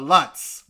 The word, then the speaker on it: Lutz: Well, it's spelled L-U-T-Z, so I'm sure that you get a lot of Lutz, but it is Lutz.